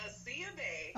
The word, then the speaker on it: Aasiya-Bey: Yeah, Aasiya-Bey.